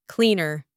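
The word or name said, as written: cleaner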